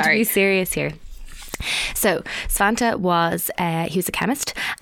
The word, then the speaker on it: Svante: Svante Arrhenius.